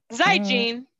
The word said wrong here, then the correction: Zaijian: Zaijian. Zaijian is how you say it in Mandarin.